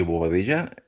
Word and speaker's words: Bobadilla: And this is Leo Bobadilla. It rhymes with tortilla.